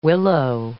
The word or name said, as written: willow